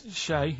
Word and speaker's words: Che: Che is like shake, and his surname is like guitar.